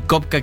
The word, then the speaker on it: Copka: Me encuentran en Instagram y en TikTok como Rob Kopka, como ya dijiste. Roberto es Rob, mi nombre. Y Kopka, que es mi apellido, se escribe con C-O-P-K-A, porque está difícil.